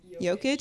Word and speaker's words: Jokic: Jokic. – Jokic.